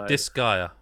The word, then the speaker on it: Disgaea: So Disgaea.